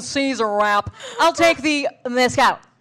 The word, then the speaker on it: mezcal: When you say mezcal, am I saying it wrong when I'm like, I'd love, can I get a spicy margarita with mezcal? Am I saying it wrong? No, that's mezcal.